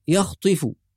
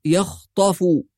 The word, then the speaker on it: يخطف: كلمة يخطف بعض القراء يقرأها يخطف ويكسر الطاء، وهذا خطأ، فالطاء مفتوحة، تنطق يخطف، يكاد البرق يخطف أبصارهم بهذه الطريقة.